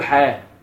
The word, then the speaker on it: ح: فالحاء دي تلاقيه موجوده في صحيح البخاري مثلا حاء، فاحنا ننطقها ايه؟ ما بنقولش تحويل اسناد، بنقول حاه. واحنا بنسرد البخاري كده، اخبرنا فلان عن فلان حاه، واخبرنا فلان عن فلان حاه.